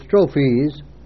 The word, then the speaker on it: strophes: That word is strophes. You put a long long line over the E in the end, and that is your pronunciation of that word, and it means a series of lines.